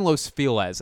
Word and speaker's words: Los Feliz: I live in Los Feliz.